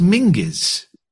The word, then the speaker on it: Menzies: So John Menzies, although I've since been told it's pronounced Mingus. – Yeah, John Mingus. Get that. I think up in Scotland, that's because Menzies is pronounced Mingus in Scotland.